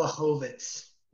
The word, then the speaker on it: Blachowicz: Blachowicz. – Jan Blachowicz versus fucking Dominic Reyes for the belt, which Reyes deserves it, if anybody.